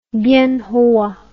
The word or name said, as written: bienhoa